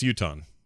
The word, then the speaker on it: Utah: Yeah, it's Utah. Hey, you got that right. We say Utah. Like if you're a U- if you're from Utah, you're a Utah. – Did you know that? Utah sounds like a race of people.